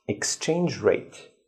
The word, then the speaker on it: exchange rate: So the stress is on the first word exchange rate.